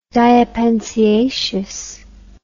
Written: diapensiaceous

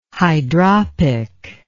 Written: hydropic

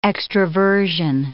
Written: extroversion

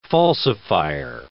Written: falsifier

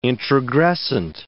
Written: introgressant

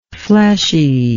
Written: fleshiness

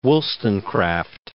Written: Wollstonecraft

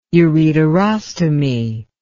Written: ureterostomy

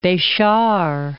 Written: Bechar

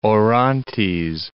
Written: Orontes

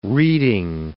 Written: reeding

